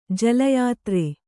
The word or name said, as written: ಜಲಯಾತ್ರೆ